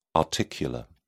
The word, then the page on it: articular: British English